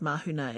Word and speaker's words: mahunga: So, for example, where I'm from, we drop NGs. So words like mahunga, the word for head, that's spelled M-A-H-U-N-G-A, while it still is written M-A-H-U-N-G-A, is actually said mahuna, and that applies the same to tangi. Looks like tangi, T-A-N-G-I, but is actually said tani.